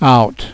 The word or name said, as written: out